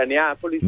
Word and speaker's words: Neapolis: Che voglio aggiungere, dell'associazione Neapolis, come si pronuncia? – Si pronuncia Neapolis.